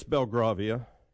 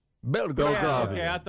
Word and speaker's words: Belgravia: I don't know, but the area is indeed pronounced Belgravia.